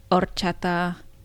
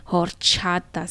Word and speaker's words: horchata: horchata snow cone. – Horchata. – Orale. – Anyway. Here's how I picture it, since I don't pronounce the H in that, even though I know you're supposed to.